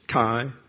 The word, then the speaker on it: chi: That's C-H-E-I. E-I is a diphthong. Gets a long I sound, so it's chi. Like if you're in the Delta Chi fraternity.